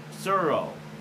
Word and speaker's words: Tsuro: But he was also playing a cool game called Suro. This is how you pronounce it. T-S-U-R-O.